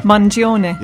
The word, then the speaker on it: Mangione: Mangione.